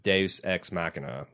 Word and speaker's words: Deus Ex Machina: is some kind of bullshit Deus Ex Machina.